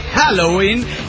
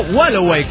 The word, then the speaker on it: Halloween: Halloween se pronuncia Halloween y es en perfecto inglés. Y no Wallaway, como tú dices.